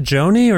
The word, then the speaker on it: Joni Void: No, do you pronounce it Joni or Johnny? – Joni. – Joni, yeah, like Joni Mitchell?